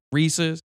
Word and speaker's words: Reese's: Again, not to be racist, but it's not Reese's Pieces. You know, the word is Pieces, so clearly it would rhyme with that, Reese's.